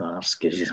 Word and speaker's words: askesis: Alltså den andliga kampen finns ju, det är alltså askesis på, om man uttalar det på kinesisk, grekiska, askesis, alltså askes.